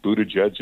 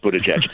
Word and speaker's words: Buttigieg: I love the way Trump would explain to people how to pronounce Buttigieg. Edge. You got to say edge.